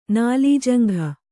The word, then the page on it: ನಾಲೀಜಂಘ: nālījaŋgha